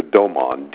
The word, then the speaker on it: Doman: This is Dr. Alexander Doman, D-O-M-A-N,